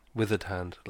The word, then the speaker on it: Withered Hand: It's pronounced Withered Hand. Apparently, Dan was saying. – Wait a minute, Withered Weather time, or oh, as in uh, on the eye. – As in like weathered.